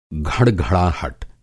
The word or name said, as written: घड़घड़ाहट